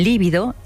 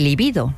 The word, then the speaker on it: libido: Por ejemplo, nos confundimos con líbido y libido. Esta no es esdrújula, sino llana, se escribe con B y no con V, y hace referencia a algo así como la energía sexual.